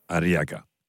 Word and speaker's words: Arriaga: Arriaga.